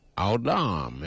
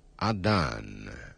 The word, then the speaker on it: Adán: El estudio es de un hombre, se llamaba Adán. Lo pronuncian Adam en el hebreo, pero nosotros decimos Adán.